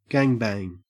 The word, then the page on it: gangbang: Australian English